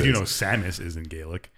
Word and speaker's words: Samus: Has it always been a thing in Metroid where like Seamus? Is it Seamus or Samus? – Samus. – Samus? – Seamus. – Well, that's how it's spelled. – Samus. No, it's not. Seamus has an E.